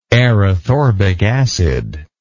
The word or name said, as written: erythorbic acid